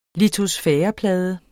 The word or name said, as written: litosfæreplade